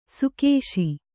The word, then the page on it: ಸುಕೇಶಿ: sukēśi